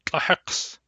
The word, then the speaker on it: tlahex: Tlahex has two syllables, tla and hex. Tla, hex.